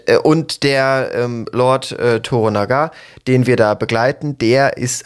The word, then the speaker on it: Toranaga: Toranaga, genau. So ist es ausgesprochen, richtig. Und in seinem Fürstentum oder in seiner Lordschaft,